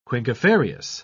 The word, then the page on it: quinquefarious: British English